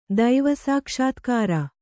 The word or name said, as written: ದೈವಸಾಕ್ಷಾತ್ಕಾರ